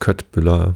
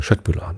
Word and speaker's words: Köttbullar: Tatsächlich kriegt man die immer als Köttbullar verkauft bei Ikea. Ich finde ja, den könnte man mal die korrekte schwedische Aussprache beibringen. Köttbullar natürlich.